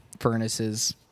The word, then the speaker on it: Furnace: And I said, oh, it's furnace, like thing in your house.